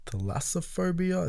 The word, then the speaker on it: thalassophobia: Um, now jumping to uh, thalassophobia.